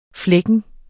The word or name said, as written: flækken